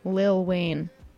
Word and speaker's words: Lil Wayne: I just said little in like a very enunciated way. I don't think I've ever done that. Lil Wayne is the correct way to say it.